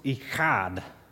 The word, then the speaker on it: echad: But it's pronounced echad, okay? You have to get that Middle Eastern thing going on in there, okay? Echad, it's E-C-H-A-D for that word one. That word does not mean in Hebrew one in isolation, just one thing by itself.